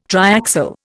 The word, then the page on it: triaxial: Indian